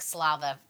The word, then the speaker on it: Badslava: It's not like saliva. – Oh, I thought it was... Well, I don't understand the word. I understood what you said. – But I spelled it correctly. – You spelled it correctly. – I said it wrong. – No. Slava is S-L-A-V-A.